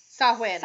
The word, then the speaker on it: Samhain: Anyway, so, it was part of Samhain, a Gaelic festival that marked the end of the harvest season. So that's, you know, around, mm-hmm. usually it was held on October 31st and November 1st. – Samhain.